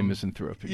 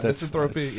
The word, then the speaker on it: misanthropy: misanthropy. And I don't even know if I said that right. And their... – Some say misanthropy. – Yeah, misanthropy, yeah. Okay.